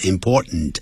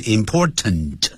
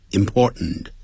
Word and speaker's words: important: 这个情况 S-I-T-U-A-T-I-O-N Situation 啊，就这样 It is important 这个 important 我常讲念的时候念成 important 不要念成 important 那个 ten 用鼻音出来 important